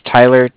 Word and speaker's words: Tyler: My name is Tyler, T-Y-L-E-R. – Okay. – And I'm calling with Cardiff. It's spelled C-A-R-D-I-F-F, as in Frank Frank.